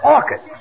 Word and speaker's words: orchids: Last night, I took my girl to a movie and bought her some orchards. – It's not orchards, it's orchids. The C-H is pronounced like K.